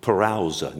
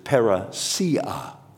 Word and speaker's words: parousia: Now, there's two ways to pronounce that in the Greek, I think. I've always called it parousa. Now, I'm sure that's probably incorrect, you know, because I'm a Texan, don't know any better. But the fact of the matter is, another way to pronounce that word, his coming, is parousia.